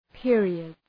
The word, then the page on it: periods: ‘pıərıədz